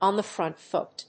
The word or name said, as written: on the front foot